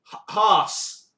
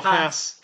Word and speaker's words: Haas: I can never get the name right. Haas. – For posh people, or Haas. – Haas.